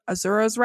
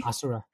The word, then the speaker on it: Asura: Azura's Wrath, is that right? – That's his name. – Ni No Kuni. – Asura.